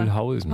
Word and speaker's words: Mühlhausen: Achso, die hintere, auf die hintere Betonung, ja, Mühlhausen.